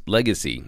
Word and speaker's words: LGCY: It's actually spelled LGCY. Its ticker is LGCY, but it's pronounced Legacy, as you see why.